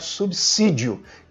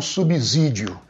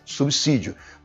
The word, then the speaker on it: subsídio: A, a, depois do prefixo sub, a letra S tem sempre o som de C, nunca de Z. Então, o certo realmente é pronunciar subsídio e não subsídio. Pronuncie como se fosse C né, com som de dois S, subsídio. Da mesma forma como você pronuncia subsolo e não subsolo.